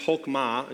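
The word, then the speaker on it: chokmah (חָכְמָה): And so if we were to be reading the Old Testament in the original Hebrew, we would find that this word wisdom, it's pronounced chokmah, and you can kind of see how it's written up there.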